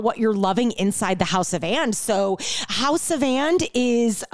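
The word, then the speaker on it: Haus of And: So we'll link up to House of And in the show notes, and it's spelt H-A-U-S of And.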